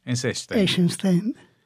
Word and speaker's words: Eisenstein: Sergei Eisenstein, o como sí. Se pronuncia en ruso, que no sé. – Eisenstein.